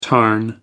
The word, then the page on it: tarn: American English